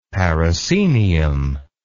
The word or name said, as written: parascenium